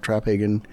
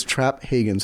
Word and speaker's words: Traphagen: It's Traphagen, so the P and the H is there. So accent on the first syllable.